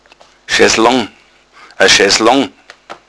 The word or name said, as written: schesslong